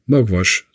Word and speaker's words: magvas: Mogwash, so spricht sich's aus, schreibt sich M-A-G-V-A-S, also fast genau wie der Name der Politikerin und bedeutet kernig.